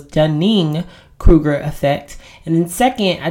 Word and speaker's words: Dunning-Kruger: One, I was on a roll and it is actually pronounced Dunning-Kruger effect, not Dunner-Kruger effect.